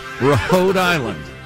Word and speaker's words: Rhode Island: Pronounces the H. – Oh. I don't believe in silent letters. The E at the end is an exception. Rhode Island.